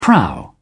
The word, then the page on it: prow: British English